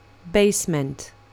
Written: basement

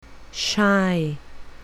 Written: shy

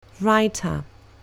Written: writer

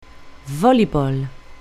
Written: voleyball

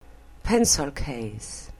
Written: pencil case

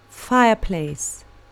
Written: fireplace

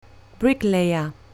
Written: bricklayer

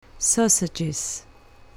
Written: sausages

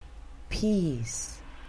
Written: peas

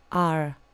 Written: r

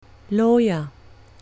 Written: lawyer

